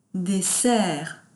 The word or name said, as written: des serres